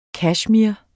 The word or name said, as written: kashmir